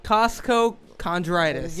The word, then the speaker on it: costochondritis: It's costochondritis. – Let me see. – It's costochondritis. Costochondritis.